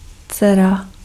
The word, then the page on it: dcera: /t͡sɛra/